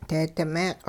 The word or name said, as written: deedi meeq'